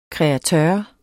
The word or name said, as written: kreatør